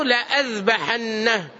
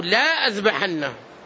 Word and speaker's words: لأذبحنه: ومن ذلك أيضا قول الله عز وجل في سورة النمل لأعذبنه عذابا شديدا أو لأذبحنه، رسمت بألفين، ولو قرأتها كما رسمت لقلت أو لا أذبحنه، لأعذبنه عذابا شديدا أو لا أذبحنه. ولذلك الألف الثانية وضعت عليها دائرة صغيرة إشارة إلى أن هذه لا تلفظ، لأعذبنه عذابا شديدا أو لأذبحنه.